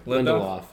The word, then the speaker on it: Lindelof: Lindelof.